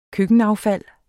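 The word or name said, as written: køkkenaffald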